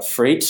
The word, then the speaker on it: Fraight: Yeah, so I work for Freight. So it's a Freight, but it's spelled FRAIGHT.